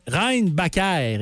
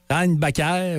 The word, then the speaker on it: Reinbacher: Non, Reinbacher, également, tu peux le prononcer. Comme Schumacher. Ouais. Reinbacher ou Reinbacher, en tout cas, peu importe.